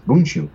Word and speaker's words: Bundschu: Here in Sonoma County, keeping it local, Gunlag Bunshu. Say that three times fast. – Yeah, I don't think I've ever pronounced that correctly. – They have it's spelled Bundschu, and it's Bunshu.